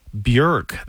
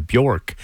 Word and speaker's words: Björk: And before that, Iceland's Björk. That is how you pronounce her name. You've been saying it. I've been saying it wrong this whole time. Björk. But in Iceland, they call her Björk.